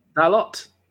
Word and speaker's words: Dalot: Dalot? – No, Martial, you don't pronounce the T. – Sancho. Huh? – You don't pronounce the T. It's Dalot.